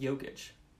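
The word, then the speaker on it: Jokić: Jokic would be fun, but I don't think he's going anywhere. They'll lock him up. – It's pronounced Jokic.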